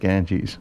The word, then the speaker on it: Ganges: Ganges? – I'm assuming it's pronounced Ganges. – Like the river.